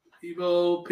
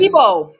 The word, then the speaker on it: Igbo: You said Igbo? I might be saying it wrong. – Igbo, Pidgin. – Igbo. Yeah. Right.